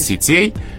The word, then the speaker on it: сетей: А дальше ударение переходит на окончание. Вот здесь. По всем падежам. То есть нет, сетями... – нет сетей, сетями, в сетях рыболовных или социальных, неважно.